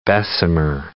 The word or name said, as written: Bessemer process